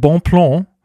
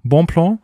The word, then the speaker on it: Bonplan: Äh, und äh, Simon hat uns geschrieben, weil äh, dessen Username habe ich letzte Folge nicht aussprechen äh, können. Es heißt anscheinend Bonplan. Bonplan.